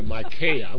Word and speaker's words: Micaiah: We pronounce this like Isaiah, Micaiah.